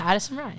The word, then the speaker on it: Addison Rae: Am I saying it right? – Yeah, Addison Rae.